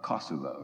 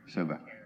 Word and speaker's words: Kosova: Well, they pronounce it Kosovo, right? Or Kosova. – Kosova.